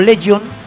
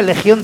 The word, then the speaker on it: Legión: Bueno, que antes de empezar con las bandas asociadas, hacer una puntualización con lo de Legion, porque siempre como cantan en inglés, lo pronuncio como Legion, DC, que realmente sería Legión DC, porque es después de Cristo en castellano, o sea, Legión después de Cristo.